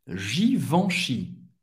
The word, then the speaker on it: Givenchy: Givenchy, Givenchy. EN en français tem o som do en. Givenchy.